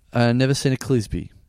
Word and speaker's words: Clisby: Sarah, I'm not mispronouncing that, hopefully, because it's spelled S-E-R-R-A. Sarah. – Sarah. – Sarah. Sure. Clisby. C-L-I-S-B-Y. – Clisby. Yeah.